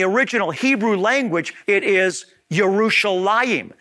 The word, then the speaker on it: Yerushalayim: the Yah sound became a J sound, as I indicated, is in the case with Yerushalayim, which is the correct way to say Jerusalem, versus Jerusalem.